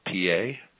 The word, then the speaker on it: Peay: But we beat them in four, then went to Clarksville, Tennessee, to take on Austin Peay, but it's spelled P-E-A-Y, so we call it P-A.